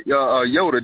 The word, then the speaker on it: Yoda: Yeah, Yoda. That's how you pronounce it.